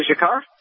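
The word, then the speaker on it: Dzhokhar: The pronunciation of the brother's name, the 19-year-old, how do you say it? – I think I say Jakar. – I think that's correct.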